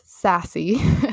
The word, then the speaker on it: SASC: It's SASC and it's pronounced sassy.